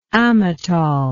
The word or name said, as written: amatol